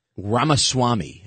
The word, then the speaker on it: Ramaswamy: Ramaswamy.